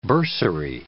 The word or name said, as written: bursary